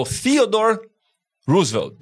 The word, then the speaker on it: Theodore Roosevelt: É o Theodore Roosevelt.